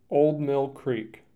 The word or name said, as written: Old Mill Creek